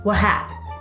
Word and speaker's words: WHAP: Yes, that spells WAP, but it's pronounced WHAP.